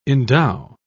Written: endow

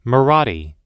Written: marathi

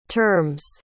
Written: terms

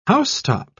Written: housetop

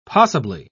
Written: possibly

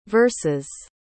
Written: verses

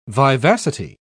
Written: vivacity

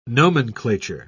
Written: nomenclature

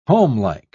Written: homelike

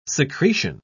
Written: secretion

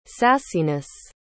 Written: sassiness

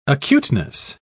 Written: acuteness

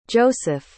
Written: joesph

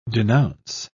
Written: denounce

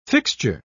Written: fixture

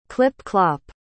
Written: clip-clop

